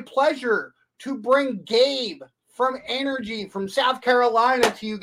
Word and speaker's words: Anergy: Anergy with a short A.